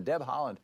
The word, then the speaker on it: Haaland: And I should say, your name, while it sounds like it's pronounced like the country, is spelled H-A-A-L-A-N-D.